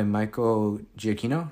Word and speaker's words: Giacchino: And I love the music, obviously, by Michael Giacchino, right? Is that how you say his name? Mm-hmm. – Yes.